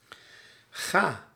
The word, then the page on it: ga: /ɣaː/